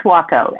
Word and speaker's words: Suokko: It's Suokko, as if it had a W.